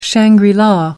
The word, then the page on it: shangri la: American English